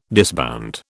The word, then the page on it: disbound: British English